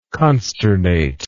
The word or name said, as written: consternate